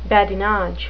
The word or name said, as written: badinage